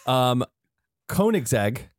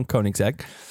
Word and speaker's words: Koenigsegg: You taught me how to say this correctly. Koenigsegg.